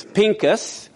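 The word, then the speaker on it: Pinchas: Here is how Phinehas' name is spelled again. It's Pincus. Going from the right to left, it's like P-I-N-C-S, whatever, Pincus.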